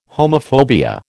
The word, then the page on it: homophobia: American English